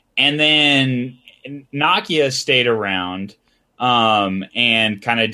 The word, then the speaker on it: Nokia: In other news, Nokia, which is how you're supposed to pronounce the brand Nokia.